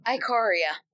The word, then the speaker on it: Ikoria: and we're recording this while Ikora, or whatever, how do you pronounce it? – Ikoria.